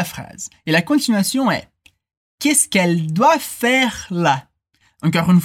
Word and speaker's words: qu'est-ce qu'elle: Attention à la prononciation, on dit qu'est-ce qu'elle Qu'est-ce qu'elle Comme si c'était un seul mot.